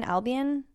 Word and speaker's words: Albion: Baby Shambles formed in 2005, and then they had two albums down in, how do you pronounce it, Albion? – Sounds good.